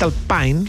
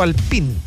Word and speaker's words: Alpine: De hecho, la transmisión oficial de F1 dice Franco Colapinto, piloto Alpine. – Pero yo pero lo dicen mal porque la pronunciación real es más Alpine. No como Alpine.